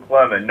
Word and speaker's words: Clemens: That's Michael Clemens, no S.